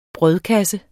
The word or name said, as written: brødkasse